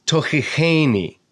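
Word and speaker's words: tochicheni (תוכיחני): So it says, Adonai al ba'afka tochicheni. There's the stress you can see right there on the cheni syllable.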